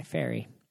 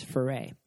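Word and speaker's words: Ferré: Our family, if you meet other Ferrets around the state, they go by Ferry. And so... – Your name is spelled... – F-E-R-R-E. But if you put an accent mark on the E, it's Ferret. And that is the proper French way to say it.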